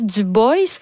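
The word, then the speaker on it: Du Bois: En fait, on prononce Dubois.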